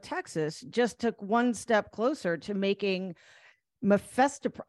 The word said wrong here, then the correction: Mifepristone: Yeah, Mifepristone.